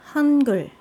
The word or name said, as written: hangeul